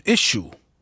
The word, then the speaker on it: Issue: Uttalar jag det rätt nu? Issue. Är det Issue? – Ja.